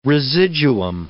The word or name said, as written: residuum